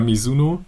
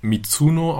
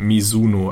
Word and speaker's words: Mizuno: Das hat nämlich auch der User Biolek unter einer der News geschrieben, dass sie nicht Mizuno ausgesprochen wird, sondern Mizuno, also mit einem weichen S.